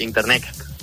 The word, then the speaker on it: InterNXT: Sí, efectivamente, Internets. Así es, lo que pasa es que se escribe InterNXT.